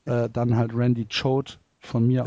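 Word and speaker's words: Choate: Sie haben trotz Randy äh, Quate Jode heißt er, glaube ich. Ja, Quate. Mhm habe ich. – Ich meine, er, er spricht sich Code? Randy Choad.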